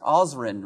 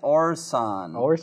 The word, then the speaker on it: Orson: Osrin, right? Orson.